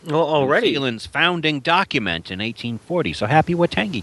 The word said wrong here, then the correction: Waitangi: Day. Waitangi Day.